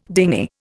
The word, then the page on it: dingy: Indian